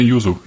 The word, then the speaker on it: Yuzu: Wie spricht man Yuzu aus? Wo betont, auf welche, wo betont man Yuzu oder heißt es Yuzu? Nee, nee, Yuzu. Yuzu.